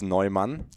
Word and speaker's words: Neumann: So I have it right now. Neumann, and it kind of emphasis on the end a little bit.